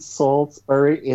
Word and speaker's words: Salisbury: Yeah, and I was on their Wikipedia page, and it's in Salisbury in, oh, I'm going to be killed for saying this wrong. It's in Salisbury in Wiltshire. Yeah, that's pretty close.